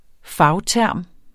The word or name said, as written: fagterm